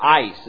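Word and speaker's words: eis: The word into is the Greek word ace, or ice is how it's pronounced.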